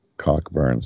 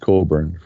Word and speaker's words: Cockburn: It's just one of those things with the English language that Cockburn. So, no, it's pronounced Colburn for anyone who's wondering.